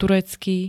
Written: turecký

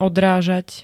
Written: odrážať

